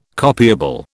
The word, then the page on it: copiable: British English